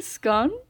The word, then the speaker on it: Scone: I think Scone. Because it rhymes.